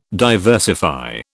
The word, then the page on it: diversify: British English